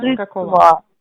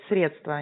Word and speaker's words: средства: Так закон вы взяли деньги чужие, удерживаете незаконно чужие денежные средства. – Правильно говорит, средства, а не средства.